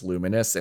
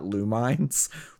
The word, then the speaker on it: Lumines: I was today years old when I found out that's pronounced Luminous and not Lumines, which I've been saying for fucking ever.